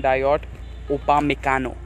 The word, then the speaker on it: Dayot Upamecano: Dayot Upamecano. I hope I said that right, guys. – Yes, you did.